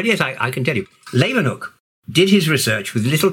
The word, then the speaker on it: Leeuwenhoek: And in the 80s, you rediscovered the power of the Leeuwenhoek microscope. I hope I pronounced his name right. – No, you didn't pronounce it right. It's Leeuwenhoek.